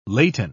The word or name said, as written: laten